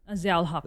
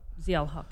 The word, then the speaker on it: Zia-ul-Haq: Zia-ul-Haq. Zia-ul-Haq, yeah.